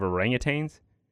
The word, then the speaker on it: orangutan: There's no G at the end of orangutan.